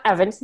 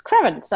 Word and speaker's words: Krievins: I just learned how to say her last name. Just learned to say that it should be, it's not Evans, it's Krevens.